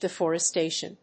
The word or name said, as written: deforestation